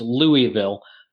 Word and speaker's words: Louisville: For those in the north, that's Louisville. I learned very quickly when I moved down here that you don't pronounce all the syllables within it and you just let it run together into Louisville.